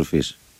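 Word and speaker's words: Fils: Τον Αρτούρ Φις. – Άρα αυτό είναι, χωρίς το S δεν τον προφέρουν οι Γάλλοι, είναι λίγο παράξενο.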